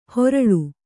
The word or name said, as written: ಹೊರಳು